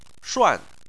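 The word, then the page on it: shuan4: tone 4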